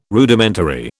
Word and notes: Rudimentary: British English

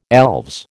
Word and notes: elves: American English

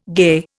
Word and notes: gay: Indian

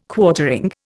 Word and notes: Quartering: Indian

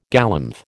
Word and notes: galumph: American English

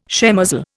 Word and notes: shemozzle: Indian

